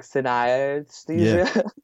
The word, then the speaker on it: synesthesia: There's actually an A in the middle of the word, so you mispronounce it like synesthesia. – Yeah. – But it's synesthesia, like anesthesia, but uh-huh.